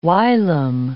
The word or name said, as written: whilom